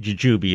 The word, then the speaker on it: jujube: How do you pronounce the tree, jujube or jujube? – Jujube is what most people say. It's more of a shrubby plant than a real tree, but yeah, jujube.